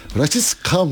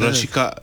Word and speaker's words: Rashica: Evet. Rashica mıymış öyle? Öyle okunuyor. – Herhalde öyle yani Makedonyalara evet. Rashica diyorlar.